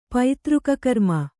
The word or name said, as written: ಪೈತೃಕಕರ್ಮ